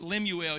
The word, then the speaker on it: Lemuel: Sort of pronounced like Samuel, but Lemuel.